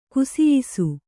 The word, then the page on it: ಕುಸಿಯಿಸು: kusiyisu